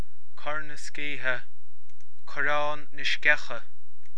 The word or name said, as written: Corrán na Sceiche